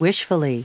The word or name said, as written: wishfully